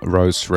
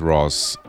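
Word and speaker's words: Röhs!: This one's the title track from the album Lene from Rose Records. Or I think it's pronounced Ross. R-O-H-S with an exclamation mark.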